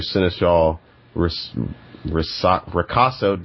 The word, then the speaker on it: Seneschal Ricasso: He is an ally with a Learned Crest who reads response after Seneschal Ricasso. Dang it, said it right the first time.